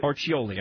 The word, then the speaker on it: Orcioli: And Mary was kind enough to track down Tony, how do you say it, Tony Orcioli? – Orcioli. – Orcioli. I'm sorry, I missed a syllable in there.